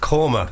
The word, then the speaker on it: korma: listen, I'm no expert, but I think that is what we in the Indian business call karma. – It's pronounced korma.